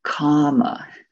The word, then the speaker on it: kama: This one has a long A, kama.